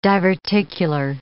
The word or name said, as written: diverticular